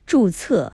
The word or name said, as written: 注册